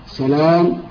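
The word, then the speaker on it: سَلام: وللمؤتلف والمختلف صور متعددة، منها: واحد، المؤتلف في صورة حروفه والمختلف في شكله، مثل سلام وسلام، الأول بفتح المهملة وتخفيف اللام، والثاني بفتح المهملة وتشديد اللام.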